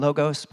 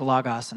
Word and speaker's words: logos: I know we all grew up saying logos, but it's actually the real pronunciation is logos, and I'm just trying to say the right word here.